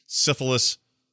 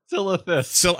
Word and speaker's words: Silithus: You'll travel to locations such as Storm Peaks, Syphilis, Oldwar. – Silithus.